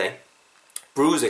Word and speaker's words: bruise: b r u i s e 의 강세를 좀 넣으세요. bruise 이렇게 길게 발음하시고 근데 is there 이 er 발음이랑 어가 붙으면서 is there a bruise?